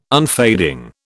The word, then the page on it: unfading: British English